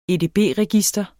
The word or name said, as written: edb-register